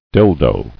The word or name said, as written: Dildo